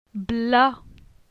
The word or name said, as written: Bla